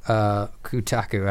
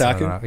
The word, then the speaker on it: Kotaku: Kotaku, I mispronounced Kotaku.